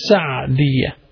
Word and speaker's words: Sa'diyah: Some people pronounce it Sa'diyah. It should be pronounced Sa'diyah.